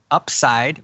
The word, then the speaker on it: UPSYD: And I've kind of reversed the order and created an acronym, UPSIDE, which is UPSYD. And it looks like UPSID, but it's upside. The reason why it's pronounced that way is because once you truly understand this,